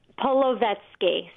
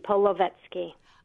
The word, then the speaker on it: Polevsky: Polevsky. So just sound it out. – Oh. – Polo, then vet, then ski. Polevsky.